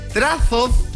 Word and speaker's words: trazo: Bueno, los chilenos no sabemos pronunciar la Z, es muy poca gente que pronuncia la Z, y por eso cada vez que yo digo trazos, suena raro, pero así se debe pronunciar.